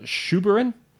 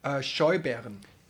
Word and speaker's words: Scheuberin: Now, in 1485, he was prosecuting a witch trial against 14 women, including a woman named Helena Schuberin. – How do you spell it? – S-C-H-E-U-B-E-R-I-N. – Schuberin. – Helena Schuberin, that sounds better.